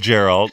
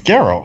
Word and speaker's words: Geralt: Is it Geralt? No, it's Geralt. We're saying it wrong. – No, it's Geralt.